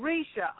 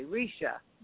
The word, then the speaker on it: Risha: Oh, Risha. R-I-S-H-A. Risha. Risha. – Okay. – Yeah, I thought that's how you pronounced it, but I didn't want to butcher it.